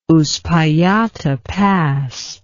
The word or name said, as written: Uspallata Pass